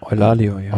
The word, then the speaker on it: Eulalio: Zwei Fahrer, Igor Arrieta und Alfonso Eulalio. Wie spricht man das aus? Eulalio? – Eulalio, ja.